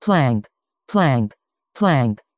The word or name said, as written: planck